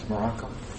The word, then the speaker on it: Morocco: Telling about a guy from Morocco's. – E ele veio exilado do Marrocos pro Brasil. – He came as an exile from Morocco's. Is that right, Morocco's? Am I saying that right? Morocco.